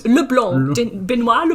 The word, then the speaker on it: Benoit: Also hier, das ist die Fortsetzung zu Knives Out, diesem Krimi-Murder-Mystery mit Daniel Craig als Detektiv Benoit Blanc, wie spricht man das? – Benoit Leblanc, okay.